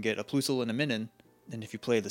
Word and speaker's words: Minun: It's minus, so Minun.